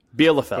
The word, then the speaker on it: Bielefeld: Bielefeld. – Correct. – Bielefeld.